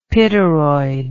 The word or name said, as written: pityroid